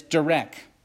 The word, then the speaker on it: derek: The word ways in this verse comes from a Hebrew word, and I'll pronounce it this way, which I believe is correct. I'm not a Hebrew scholar, okay? But it's derek, derek, D-E-R-E-K.